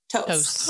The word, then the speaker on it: Toce: T-O-C-E. Toast. – Oh, yeah. – Yeah. – Not Toast. No, yeah, not like Toast.